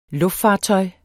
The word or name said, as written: luftfartøj